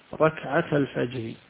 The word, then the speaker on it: ركعة الفجر: ركعة الفجر خير من الدنيا وما فيها. ركعة الفجر فيها ألف، لكن هذه الألف همزة وصل فلا تنطق، توصل مع التي بعدها ركعة الفجر.